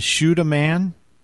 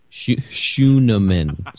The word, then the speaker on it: Scheunemann: Wait, his last name is Schuneman? – Schuneman. Schuneman. – Okay, thank you. – S-C-H-E-U-N-E-M-A-N-N.